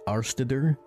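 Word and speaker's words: Árstíðir: It looks really weird when you see it written out. It's pronounced Arstider.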